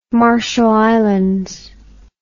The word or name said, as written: Marshall Islands